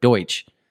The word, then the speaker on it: Deutsch: But yeah, like I definitely, yeah, I know that because that's where the whole like Pennsylvania Dutch thing is actually a mispronunciation of Deutsch.